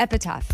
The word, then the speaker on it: epitaph: An epitaph? Epitaph? Oh. Epitaph. – So it's like when someone dies. That's what that is. – Is that how you say it? – Yeah, sure. – Yeah, cool. Epitaph.